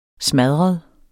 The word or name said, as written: smadret